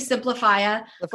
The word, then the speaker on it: Simplifya: We tried to simplify the name, but it's always mispronounced. So the company's actually Simplifia.